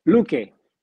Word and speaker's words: Luque: Loke again. What's the correct pronunciation? – Luque. Adolfo Luque.